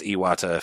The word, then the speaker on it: Iwata: it sounds a lot like he says Satoru Iwata, even though it would mm-hmm. be pronounced Iwata if it was Japanese, but yeah, no, I thought it really sounded like Satoru Iwata in one of the dialogue things.